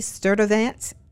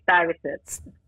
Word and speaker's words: Stuyvesant: Sturtevant. I'm not saying it right. Say it for me. – B. Sturtevant.